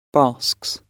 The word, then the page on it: basks: British English